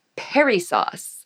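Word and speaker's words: perissos: The word abundantly, I have a hard time saying this without laughing, but the word abundantly in the Greek is pronounced perisos.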